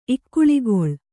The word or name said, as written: ಇಕ್ಕುಳಿಗೊಳ್